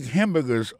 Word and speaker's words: hamburger: On a remarqué que dans le mot hamburger, ça, comme tu as dit, il y a trois syllabes là-dedans. Quand tu appuies et puis plus ça, c'est sous premier syllabe là.